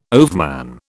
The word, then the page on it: overman: British English